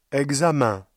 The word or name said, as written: examen